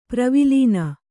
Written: ಪ್ರವಿಲೀನ

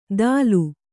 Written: ದಾಲು

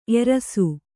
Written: ಎರಸು

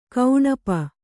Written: ಕೌಣಪ

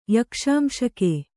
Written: ಯಕ್ಷಾಂಶಕೆ

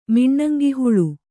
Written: ಮಿಣ್ಣಂಗಿಹುಳು

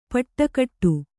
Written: ಪುಟುಗುಟ್ಟು

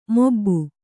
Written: ಮಬ್ಬು

